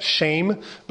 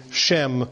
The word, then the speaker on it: Shem: It's pronounced shame, but given the way that that word works in English, I'm going to be calling him Shem throughout.